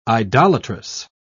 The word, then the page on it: idolatrous: British English